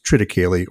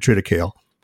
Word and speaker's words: triticale: So for me, I use triticale, or as some people pronounce it, depending on what part of the country you're in, triticale.